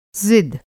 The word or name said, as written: ज़िद